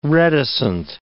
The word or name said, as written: reticent